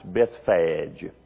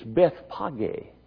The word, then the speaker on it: Bethphage: Many times I've heard it pronounced Bethphage. It's Bethpage. It means the house of the young fig.